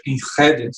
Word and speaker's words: Enschede: Fiquei uh, meio ano a fronteira com a Enschede, se pronuncia com gutural, nem sei falar direito aquilo,